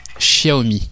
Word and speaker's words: Xiaomi: enfin, donnez-moi un X. Oui, mes amis. Xiaomi. C'est comme ça qu'on prononce.